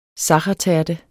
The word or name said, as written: sachertærte